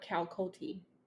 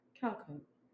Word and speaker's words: Calcote: But the last name, because it's spelled C-A-L-C-O-T-E. – You're not laughing, too. It's a go-getter. – Some people say Calcote. I get a lot of Calcote. Like, they put the T at the end when it's just Calcote.